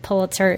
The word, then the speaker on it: Pulitzer: But I think for Kara, she probably needs to learn how to pronounce Pulitzer, because she said it as Pulitzer. And if you're going to be a reporter, you might need to know that Pulitzer is the correct way to pronounce it, because that is the way Joseph Pulitzer said it, and that's the way he liked it.